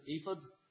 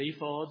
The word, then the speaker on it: ephod: Now, the word ephod, some people pronounce it ephod, but I always grew up pronouncing it ephod, so we'll keep pronouncing it that way.